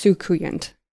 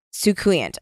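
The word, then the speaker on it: soucouyant: I think, by the way, it's pronounced Sukuyant. – Sukuyant.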